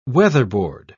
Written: weatherboard

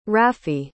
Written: rafi

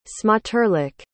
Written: smoterlich

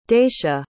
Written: daisha

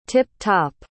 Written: tip-top

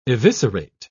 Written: eviscerate

